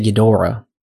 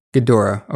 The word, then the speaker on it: Ghidorah: So I've always said Ghidorah, but in the movie, they say Ghidorah. – Ghidorah, okay. I'll say Ghidorah for the movie's sake. – But I like to say Ghidorah.